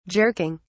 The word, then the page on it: jerking: British English